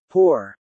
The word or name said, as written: poor